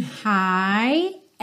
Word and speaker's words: high: but I know I'll be hunting high and low. Lembrando, esse hi tem a mesma pronúncia do hi, hello, how are you doing, ok? Hi, então esse GH você não precisa se preocupar com ele.